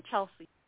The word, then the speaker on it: Chelsea: I'm Chelsea. Yeah. – Can you spell that, please? – C-H-E-L-S-E-A.